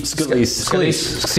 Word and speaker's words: Scalise: Steve Scalise.